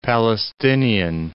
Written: Palestinian

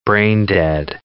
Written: brain-dead

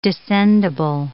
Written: descendible

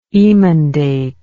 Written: emendate

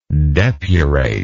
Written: depurator

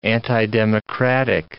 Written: antidemocratic

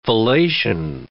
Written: fellation

